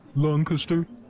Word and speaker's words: Lancaster: I like to hit Lancaster, Pennsylvania. I guess it's actually pronounced Lancaster. You kind of pronounce it like Thurston Howell is the proper pronunciation.